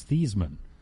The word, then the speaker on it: Theismann: It's not Theismann, it's Theismann." – Oh, seriously? – And the guy said to his dad, "Well, yeah, but Theismann rhymes with Heisman."